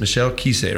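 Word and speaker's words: Michel Qissi: So this is Michel Kiese, right? That's how you say his name.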